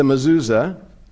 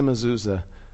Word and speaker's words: mezuzah: It's called a mezuzah. Did I pronounce it right? More or less. And inside the mezuzah is the Shema.